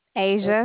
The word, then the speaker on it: Asia: Yes, my name is Asia. – Ava? – Asia.